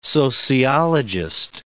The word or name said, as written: sociologist